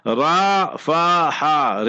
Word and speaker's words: Rafah: Ra-fa-ha. So, oh, you say Rafah. So that is the English terminology. In Arabic, you say Rafah. Ra-fa-ha. Re-fe-he.